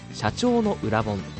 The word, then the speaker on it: 社長の教科書: 今回はですね、会社の社長、経営者、そして実業家の皆さんにおすすめしたい一冊、インフォトップ出版より出ております中村司著、社長の裏本という本です。社長の教科書と書いてね、あの裏本と読ませるんですけども、一体どんな裏テクニックがあるんでしょうか。